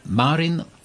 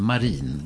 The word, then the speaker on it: Marin: Sanna Marin, eller möjligen Marin. Det finns 2 olika uttal.